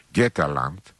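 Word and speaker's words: Götaland: Γκέτελαντ, σας το λέω και με σουηδική προφορά, είναι η χώρα των Γότθων.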